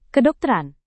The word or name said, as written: kedokteran